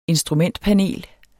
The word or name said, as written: instrumentpanel